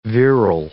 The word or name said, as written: virile